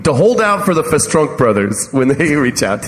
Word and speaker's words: Festrunk: The Festrunk brothers. – Okay, all right. That's the Bohemian pronunciation.